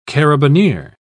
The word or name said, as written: carabineer